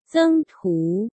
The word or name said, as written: Zeng Tu